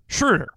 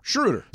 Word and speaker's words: Schröder: How do you say his name? – Schroeder. – Schroeder.